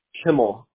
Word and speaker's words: Kimmel: Kimmel. – How do you spell that? – K-I-M-M-E-L.